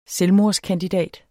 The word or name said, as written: selvmordskandidat